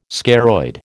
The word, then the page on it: scaroid: American English